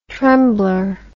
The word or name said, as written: tremblor